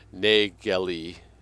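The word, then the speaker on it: Nägeli: An A with an umlaut to make it pronounced A rather than ah. Nageli. And that may be an odd, strange, kind of like N-A-G-E-L-I.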